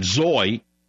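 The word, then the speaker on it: Zoi: She's an Al Gore acolyte, Zoy, which rhymes with soy.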